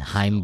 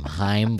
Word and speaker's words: Chaim: And Chaim Bloom. That's how you say his name. Chaim Bloom. Not Chaim or whatever the heck. Chaim. – I don't know how many times it's Chaim Bloom.